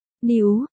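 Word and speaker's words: niú: Lợi hại, 666, cách đọc của số 666 lại có phần tương đồng với chữ new phát âm là new, hàm ý của từ này là để khen ngợi một người quá giỏi và có khả năng cực kỳ.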